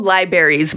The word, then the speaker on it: library: They're called libraries, by the way. – Liberries? – Yeah, it's lie and then berry.